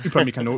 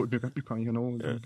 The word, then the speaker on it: Upamecano: Upamecano, Upamecano, sag genau. du. Ja, den, dem würde ich jetzt noch ergänzen. Entweder Upamecano oder Konate, also ähm, 20 Upamecano und äh,